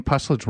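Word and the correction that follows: Puslinch: Well, as think about hanging cats in trees. So a puss lynch. So pussy cats lynched in trees.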